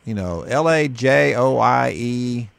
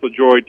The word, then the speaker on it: Lajoie: Is it LaHoy? L A J O I E. I believe that's Bill Lajoie. He's dead now. – He is dead. Lajoie, is that how he pronounced it? – Bill Lajoie, yeah. – Wow. Anyway, he said... – Well, I take that back. I've heard it pronounced LaJoy, too, but I always go back to...